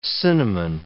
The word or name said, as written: cinnamon